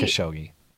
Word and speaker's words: Khashoggi: Cypher Ace, Khashoggi. Am I saying that right? – I think so. I've heard some weird pronunciations of it from people who sound like they're trying to pronounce it correctly in, you know, in whatever language it's supposed to be pronounced that way. But I'm just going with Khashoggi.